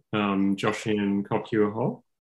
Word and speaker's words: Joshin Kokyuho: So, I've been doing, I'll probably mispronounce it, Joshin Kokyuho. – You did very good.